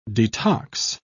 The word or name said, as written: detox